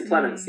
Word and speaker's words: Plemons: It's with Jesse, I don't know how to say his last name, Peemans. He's the one married to Kirsten Dunst. – Oh, Jesse Plemons, yeah.